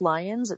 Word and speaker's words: Lyons: So if you want to friend me on Facebook, it's Brooke Lyons. It's spelled weird. It's B-R-O-O-K-H. And my last name is Lyons, L-Y-O-N-S.